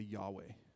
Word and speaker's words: Yahweh: That is the tetragrammaton, Y-H-W-H. In Hebrew, Yod, He, Vav, He, Yod, He, Vav, He. Y-H-W-H, we say Yahweh. That's how we pronounce it.